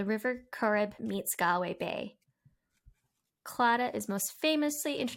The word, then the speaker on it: Claddagh: Claddagh.